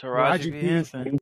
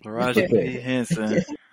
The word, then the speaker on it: Taraji P. Henson: Is it Taraji Henson? Is that how you say it? – Taraji P. Henson. Taraji P. Henson.